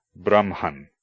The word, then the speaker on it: Brahman: In the combination HM, the H was originally pronounced before the M, with a sound somewhat like the CH in Scottish loch, or German ich. Today, HM is usually pronounced as MH. Examples include Brahman, the impersonal absolute, and Brahma, the individualized deity.